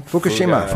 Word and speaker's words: Fukushima: Fukushima.